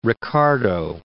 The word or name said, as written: Ricardo